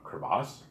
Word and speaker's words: crevasse: crevice, a crevasse, did I say it right? – Yeah.